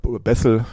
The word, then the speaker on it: Bethel: Und ja, Bethel Church in Bethel, dann Englisch ausgesprochen, in Redding, in Kalifornien, USA.